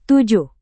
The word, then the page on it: tujuh: indonesian